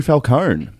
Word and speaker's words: Falcone: Our neighbour will be the new Falcone. And we also have an additional bit to go with this. – So we have a... Huh? – Apparently you pronounce the E.